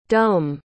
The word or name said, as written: dum